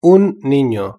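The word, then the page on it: un nino: Spanish (Spain)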